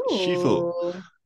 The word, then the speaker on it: Sifu: Sifu, uh, by Sloclap Games.